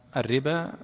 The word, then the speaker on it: الربا: وهي ليست مطابقة للنطق، فهي الربا باء ألف، لكنها مكتوبة في القرآن الربو وبعدين ألف بعدها.